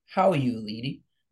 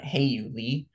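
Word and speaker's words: Hao-Yu Lee: Well, first of all, I'm pretty sure it's pronounced How You Lee, not Hey You Lee.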